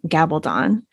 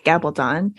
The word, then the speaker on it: Gabaldon: And I think as a bonus, if you do love Outlander or you're intrigued by that TV series, Diana, I think she pronounces her last name Gabaldon because it's like a Spanish last name. I always want to say Gabaldon, mm-hmm. but it's Gabaldon. She has what she calls her methadone list,